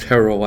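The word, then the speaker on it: Herro: Yeah, we How does a guy like Hero, pronounced Hero, I believe.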